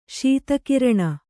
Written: ಶೀತಕಿರಣ

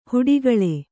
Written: ಹೊಡೆಗುಳು

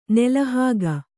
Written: ನಾಲಾಹಿಕ್

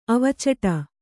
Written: ಅವಚಟ

